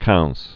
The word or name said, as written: Louis Quinze